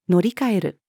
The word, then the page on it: 乗り換える: female